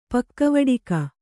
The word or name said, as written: ಪಕ್ಕವಡಿಕ